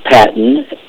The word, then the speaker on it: Patton: Okay, my name is Jude Patton. It's spelled J-U-D-E. Last name is P-A-T-T-O-N.